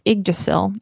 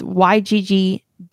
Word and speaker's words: Yggdrasil: It's Yggdrasil. It's Yggdrasil.